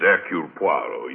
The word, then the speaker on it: Hercule Poirot: If I may be permitted the one slight correction, my name is Hercule Poirot. Your good sister proceeds on the familiar English assumption that we are not English, do not know how to pronounce our own silly names.